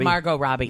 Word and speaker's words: Margot Robbie: That's Margot Robbie.